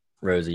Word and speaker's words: Rosi: How do you say it? Rosie? – Rosie, yeah.